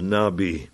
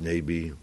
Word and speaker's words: Nabi: Dat komt omdat de profeet, dat weet iedereen, hè, profeet, dat is Nabi. Dat is Nabi, of Nebi, hoe ze het maar uitspreken, Nabi.